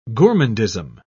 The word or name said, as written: gourmandism